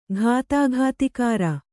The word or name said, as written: ಘಾತಾಘಾತಿಕಾರ